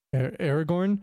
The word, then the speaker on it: Aragorn: Aragorn? – Yeah.